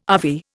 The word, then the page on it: RV: Indian